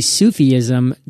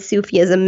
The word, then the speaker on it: Sufism: Now, I think I heard you say Sufism. Did I pronounce that correctly? And if so, you did.